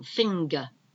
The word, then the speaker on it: finger: And we've got finger, same thing, finger. It's like an A, but it's not, it's finger, F-I-N-G-E-R, finger. We don't pronounce the R. Surprise, S-U-R-P-R-I-S-E.